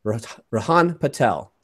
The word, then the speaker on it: Rihaan Patel: And you, my friend, you are Rihaan Patel.